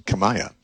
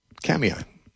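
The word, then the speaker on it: Kamiah: it doesn't look like it should be pronounced Kamiai. It looks like it should be pronounced Kamaya. It's K-A-M-I-A-H. I guess it's a Nez Perce Indian word, and they pronounce it Kamiai.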